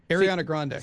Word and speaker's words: Ariana Grande: Ariana Grande, is that right? – Ariana Grande.